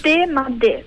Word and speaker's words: De Madè: Allora, io leggendo così velocemente il titolo avevo letto De Madè, cioè mi ero rifatto un po' al francese, ma immagino che si pronunci alla livornese, giusto? – Sì.